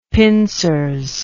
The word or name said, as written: pincers